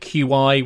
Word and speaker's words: Qi: There are a couple of different flavours, like QI, which they pronounce Qi and stuff, but it's becoming more and more of a standard.